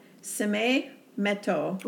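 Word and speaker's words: Sine Metu: So the second thing I thought was really interesting is that the motto, Seme Meto. – Wow, you did good.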